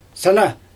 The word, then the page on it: sana: Xyzyl